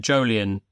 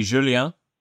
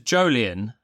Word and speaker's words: Jolien: So I didn't have too many problems with it, but Jolien is how you say my name. But if I speak to Canal Plus and they're French and they call me Julien. It's not the same as Jolien.